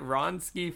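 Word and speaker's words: Wronski: How do you say it? Ronsky faint? Is that how you say it? – Yeah.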